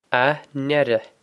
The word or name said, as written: a n-eire